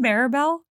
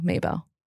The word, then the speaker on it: Mabel: Did I say isn't it Maribel? – No, that's how I used to say it wrong. It's M-A-B-E-L, Maybell.